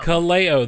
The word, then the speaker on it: Kaleo: Kaleo.